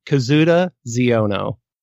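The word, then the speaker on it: Kazuda Xiono: It follows Kazuda Ziono. Is how I believe that they pronounced it.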